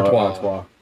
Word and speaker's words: Artois: How do you pronounce that? – Stella Artois. Some people say Stella... What is it? – Artois.